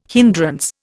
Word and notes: hindrance: Indian

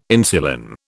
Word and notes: insulin: British English

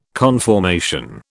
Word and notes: conformation: British English

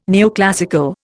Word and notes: neoclassical: Indian

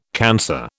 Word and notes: cancer: British English

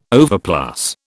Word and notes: overplus: British English

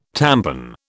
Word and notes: tampon: British English

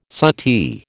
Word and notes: suttee: American English

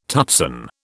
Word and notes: tutsan: British English

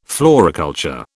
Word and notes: floriculture: British English

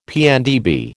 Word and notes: PNdB: American English

